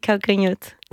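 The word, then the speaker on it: Calcanhotto: Oui, Calcanhoto.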